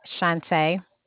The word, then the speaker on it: Shante: Shante. – Can you spell that for me, Shante? – S-H-A-N-T-E.